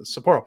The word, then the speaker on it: Sapporo: Sapporo. Why do I keep saying I put the emphasis on the wrong syllable there? Sapporo.